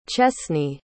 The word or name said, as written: chesney